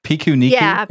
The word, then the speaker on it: Pikuniku: this is my attempt. I think it's Pikaniku. Because it's Japanese, and I Pinocchio. Think part of that I got correct, but I'm not sure. – Pikuniku is what I would go with.